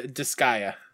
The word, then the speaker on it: Disgaea: What's Dissigia? Is that how you really say it? JRPG thing. I'm not picking it. I'm just curious of how you pronounce it. – Yeah, it's a Disgaea.